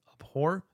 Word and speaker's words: abhor: The word abhor, I think that's how you say it, right? Abhor. It's not a silent H.